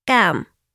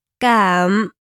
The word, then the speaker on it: cảm: In Vietnamese, thank you is cảm ơn. Let's break it down. Cảm ơn. Once more. Cảm ơn. The first word, cảm, means to feel. Cảm, cảm.